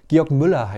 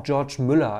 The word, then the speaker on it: Georg Müller: Manchmal auch George Müller, also weil der, es ist ein Deutscher, der aber dann nach England gegangen ist. Und mal wird er so, mal so ausgesprochen, aber eigentlich Georg Müller.